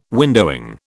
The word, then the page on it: windowing: British English